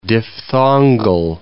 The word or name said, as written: diphthongal